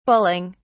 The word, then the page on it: bulling: British English